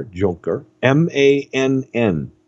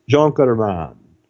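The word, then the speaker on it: Junkermann: And the last name is spelled J-U-N-K-E-R, Junker, M-A-N-N, Mun. Junkerman. Junkerman.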